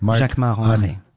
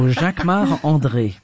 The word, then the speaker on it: Jacquemart-André: Jacquemart-André. You say it like André. A Frenchman. – I'm French. So Jacquemart André.